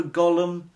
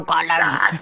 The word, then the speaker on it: Gollum: The Hunt for Gollum. Which is good. – Sorry, you didn't say that right. The Hunt for Gollum.